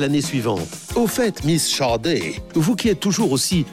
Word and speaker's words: Sade: Ça s'écrit Sad, S-A-D-E, comme le marquis, mais ça se prononce Sade.